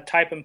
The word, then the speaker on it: Teipen: They can be purchased on my website at Teipen Pipes, and that's Teipen, T-E-I-P-E-N, Pipes. It's not spelled like it sounds, .com.